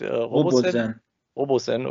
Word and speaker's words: Robosen: Haben wir uns jetzt eigentlich auf eine Betonung, Aussprache geeinigt? Robosen, okay.